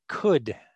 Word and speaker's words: Qud: So anyway, next month we are going to play Caves of Qud, is how I will pronounce it. Q-U-D, Caves of Qud, by Freehold Games.